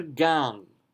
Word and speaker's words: Gang: Oi brennen wet auf deures Lang und beleichten unser Gang. So beleichten, tien, and lang and gang, you do pronounce the G.